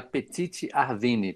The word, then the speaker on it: Petite Arvine: Heida, e a Petite Arvine. Petite, como se pronuncia, e Arvine, Arvine.